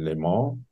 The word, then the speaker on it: Le Mans: Ich fand Französisch immer doof, deswegen hoffe ich, dass ich es richtig ausspreche. Le Mans. – Ja, das stimmt, glaube ich. So wird es auch in dem Film ausgesprochen.